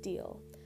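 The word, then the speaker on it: Dalyell: That's D-A-L-Y-E-L-L, but the pronunciation little note that I saw was that the name is pronounced Deal.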